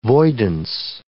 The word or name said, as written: voidance